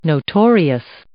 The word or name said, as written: notorious